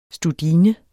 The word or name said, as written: studine